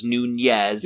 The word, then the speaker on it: Nunez: It would be pronounced Nunez. – It does have the Nye.